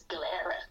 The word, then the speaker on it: Galera: I'm like, it's Galera. As if they'd know. – Yeah, it's like, it's basically pronounced as it's spelt. It's kind of phonetically spelt.